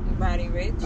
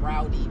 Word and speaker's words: Roddy Ricch: Is it Roddy or Roddy? How do you say his name? Is Roddy Ricch? – Roddy Ricch. – Okay, yeah. – I'm saying it right. I keep wanting to call him Rowdy, but that's another rapper.